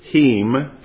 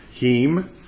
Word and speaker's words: him: Well, we say him, but if we were pronouncing it like they did, heem, okay? The I is kind of pronounced like an E. Heem.